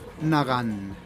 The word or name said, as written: nagan